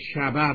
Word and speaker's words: شبه: جز این که فقط یه یادآوری من بکنم به سنازبانو، خواهش میکنم که این شبه که خوندید ه رو نباید در این واژه تلفظ کرد. این ه به اصطلاح ادبی قدیم یا به اصطلاح مستور زبانی قدیم ه غیر ملفوظه، یعنی شبه باید تلفظ کرد.